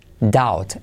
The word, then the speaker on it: doubt: No se dice doubt, sino doubt. Doubt. Ese au es au y la B no se pronuncia y la T.